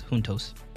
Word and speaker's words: juntos: Juntos. Like Juntos. It's spelled like Juntos, but it's Juntos.